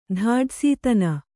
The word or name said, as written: ಧಾಡಸೀತನ